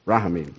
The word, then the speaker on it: Rahamin: It's ramin. That's a Hebrew word. Did I say it right? Nope. Rahamin. That's right, that's right.